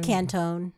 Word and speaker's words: Cantone: Cantone.